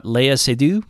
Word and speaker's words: Seydoux: And then he brought Léa Seydoux.